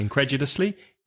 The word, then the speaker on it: incredulously: Now, that's a long word, five syllables, incredulously, incredulously, stress the second, incredulously.